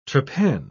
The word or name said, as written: trepan